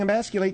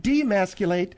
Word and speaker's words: emasculate: Phil, you weren't pronouncing emasculate. You said demasculate.